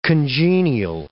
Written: congenial

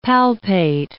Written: palpate

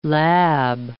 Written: lab

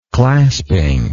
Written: clasping

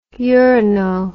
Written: urano

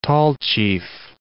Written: Tallchief

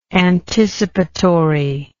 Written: anticipatorily

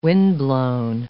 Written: windblown

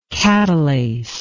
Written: katalase

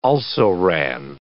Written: also-ran

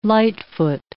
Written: light-foot

